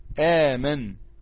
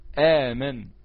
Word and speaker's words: آمن: كلمة آمن دي كان أصلها أأمن، أأمن. الهمزة الساكنة ديت بدلناها الألف لأن الهمزة الأولية مفتوحة، وبالتالي بقى نطقها اللي هنطقها في القرآن آمن، آمن.